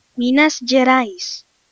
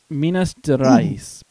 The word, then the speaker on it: Minas Gerais: Minas Gerais, Portuguese. – Minas Gerais, Portuguese.